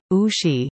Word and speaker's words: Ouchy: Lausanne has two tourist zones, the idyllic waterfront area of Ouchy, pronounced Ouchy, and the tangled and historic old town, directly uphill from the lake.